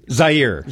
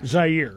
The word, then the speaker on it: Zaire: Zaire. – Zaire.